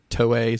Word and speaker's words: Toei: The story that line is taken from, is it Toei? Is that how you say it? – Yes. – Toei in Japan with a toy.